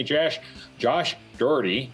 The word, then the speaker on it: Dougherty: Today, I've got with me Josh Dougherty.